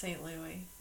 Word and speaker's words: St. Louis: Is it St. Louis or St. Louis? – It's pronounced St. Louis.